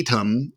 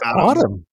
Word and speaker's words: Atum: You know, Atom, I think it's Autumn. I think Autumn? They said it was Autumn. Yeah. – Oh, okay. God, yeah. I've been calling it Atom this whole time. – Because it's spelled A-T-U-M. So it's easy to go Atom.